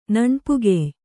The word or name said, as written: ನಣ್ಪುಗೆಯ್